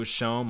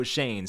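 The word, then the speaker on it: Schoen: So next we have Joe Shane. I always thought it was Joe Shone, my dumbass. But I actually just watched the video. I saw it was Joe Shane, so I wanted to nail that pronunciation.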